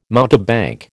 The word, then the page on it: mountebank: American English